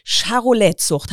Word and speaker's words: Charolais: Du betreibst ja eine Charolais-Zucht, habe ich das richtig ausgesprochen? – Genau,